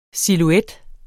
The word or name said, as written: SILHUETTERNES